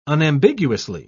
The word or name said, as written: unambiguously